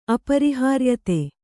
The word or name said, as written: ಅಪರಿಹಾರ್ಯತೆ